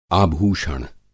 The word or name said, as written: आभूषण